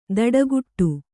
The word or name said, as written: ದಡಗುಟ್ಟು